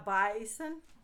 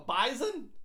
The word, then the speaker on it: bison: A bison. That's what it's called. – You it's called a bison. You you say it like there's a Z in it. A bison.